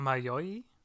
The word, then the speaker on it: Mayoi: like have Araragi, Mayoi, Mayai, uh, Mayoi. Yeah, Mayoi. Mayoi.